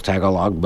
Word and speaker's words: Tagalog: T-A-G-A-L-O-G. I thought there was no pronunciation on any of the syllables, Tagalog, but it turns out...